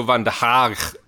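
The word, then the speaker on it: van der Gaag: Mitchell van der Haag, as we were told to pronounce it by Marcel on Monday. I don't know how long I'm going to be able to do that for, in fairness, but that is roughly how you pronounce his name correctly.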